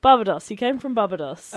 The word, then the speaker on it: Barbados: Nah, I thought that as well, but now I'm looking and I'm thinking, maybe is it Barbados? Is that right? Am I saying that right? – Barbados. – Oh, thank you.